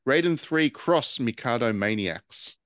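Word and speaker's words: Raiden III × Mikado Maniacs: The X is pronounced cross. – Oh, is it? Raiden 3 cross Mikado Maniacs.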